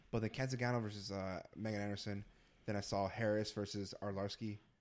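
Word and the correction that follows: Arlovski: No. How you say it? – Arlowski. – God damn. Arlowski. – There's an L in there. Just think about that. Arlowski.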